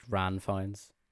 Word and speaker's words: Ranulph Fiennes: And he has like a great uncle or something called Ranulph Fiennes, pronounced Ran Fiennes.